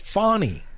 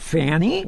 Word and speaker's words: Fani: So, well, you keep pronouncing her name wrong. It's Fonny. – Fonny. Well, it's F-A-N-I. Isn't that Fanny?